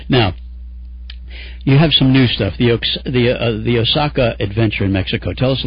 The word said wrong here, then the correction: Oaxaca: It's pronounced Oaxaca.